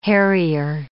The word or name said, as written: harrier